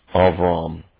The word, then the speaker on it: Avram: But Abram, his name is Avram in Hebrew.